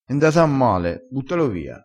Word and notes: nda a male: dialettale

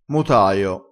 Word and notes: motaio: dialettale

